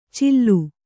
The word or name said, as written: ಚಿಲ್ಲು